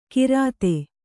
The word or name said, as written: ಗಿರದೆ